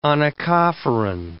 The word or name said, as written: onychophoran